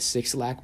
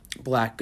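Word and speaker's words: 6LACK: So on the contrary, on the other side of relationships, you have the artist named Black, commonly said as Six Lack, but it's pronounced Black.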